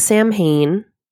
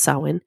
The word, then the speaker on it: Samhain: It looks like Samhain. It's pronounced Samhain.